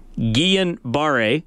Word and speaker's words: Guillain-Barré: Blair says, the pronunciation of the syndrome is Guillain-Barré.